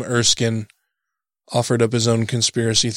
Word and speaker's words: Erskine: Erskine Radio? – Erskine. – E-R-S-K-I-N-E? Erskine?